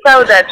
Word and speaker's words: Saudade: Et au niveau de ce nom, Saudage, si je prononce bien, c'est bien comme ça que ça se prononce. – Oui, c'est ça, c'est Saudade.